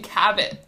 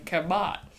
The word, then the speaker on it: Cabot: Are we sticking to Kabat or? – It's supposed to be Cabot. My whole childhood, I thought Meg Cabot was pronounced Meg Kabat.